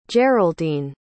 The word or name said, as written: jeraldine